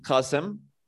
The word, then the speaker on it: Kasim: So my name is Kasim. It's an Arabic name, so you can't pronounce it. So you can just say Kasim.